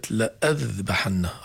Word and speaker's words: لأذبحن: كلمة لأذبحن رسمها خاص، في ألف زائدة إلها معنى دلالي ولكن لا تلفظ، طبعا ما أحد أخطأ فيه ولكن للبيان.